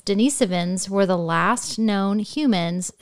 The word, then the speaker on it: Denisovans: Did I say that right that time? – Kind of. – Say it for me again. – The Denisovans. – Denisovans, okay.